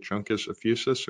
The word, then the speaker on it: Juncus effusus: Is it Juncus effusus, or is it pronounced differently? – Be correct.